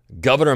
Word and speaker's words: Gouverneur: And one of the things I bring up in the Founding Fathers course is something that Governor Morris said, or Gouverneur Morris of New York, however you want to say his name. Abigail Adams said it was pronounced governor. It's spelled like gouverneur.